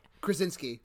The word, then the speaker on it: Krasinski: And John Krasinski, how do you say his last name? – Krasinski. – Krasinski.